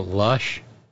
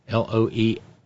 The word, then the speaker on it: Loesch: Dana Lush, Ralphs, Loesch. She always sounded drunk, so I always figured Lush was the correct pronunciation.